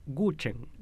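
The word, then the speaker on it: Guzheng: Es Guchen.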